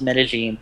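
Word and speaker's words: Medellín: Don't ask me how you get like a ZH or a J sound from two L's. I don't know. You'll hear it both ways, in all honesty, but it's properly pronounced Medellin, as I've been told many, many times by many, many people.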